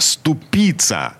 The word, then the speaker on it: ступица: Значит, мы специально перед началом этой программы заглянули на сайт грамота.ру, и грамота нам сказала, что э, ступица на второй слог э, ударяется это слово.